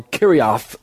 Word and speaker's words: Kirjath-Jearim: And so notice in verse 1 of chapter 7, the men of Kirjath-Jearim, or Kiriath, some people pronounce it, Jearim, came to get the Ark of God and bring it to the house of Abinadab, who lived on the hill.